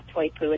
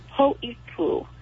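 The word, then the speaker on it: Poipu (Poʻipū): Three of the biggest ones that I just really want our community to reflect upon are Poipu, which is not Toipu. It's actually Poipu. That's the correct way to say it. P-O with an Okina. P-O with a Macron, Okina I-P-U. So that word is Poipu.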